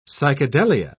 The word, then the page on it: psychedelia: British English